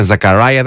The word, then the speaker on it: Ζακαράια: Έτσι προφέρεται τελικά. Είναι Ζακαράια, δεν έχει σχέση με Ελλάδα.